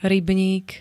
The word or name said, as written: rybník